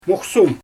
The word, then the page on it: muxsum: Xyzyl